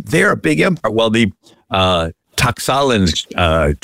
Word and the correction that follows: Tlaxcalan: tribe that had been persecuted, and I know I'm not pronouncing that right, Tlaxcalan, D-L-A-X-C-A-L-A-N.